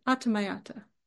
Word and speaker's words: atamayata: It's actually said several different ways. So if you've heard a teacher say it a different way, almost think of it as like different emphasis on different syllables from different lineages, but this is how I was taught to say it, atamayata.